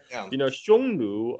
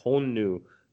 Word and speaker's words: Xiongnu: That's a great question because Xiongnu, or that's in the modern Mandarin pronunciation, back in the days, he's probably pronounced something like Honnu because the Sanskrit, according to the Sanskrit transcription, they're called Hunna, right?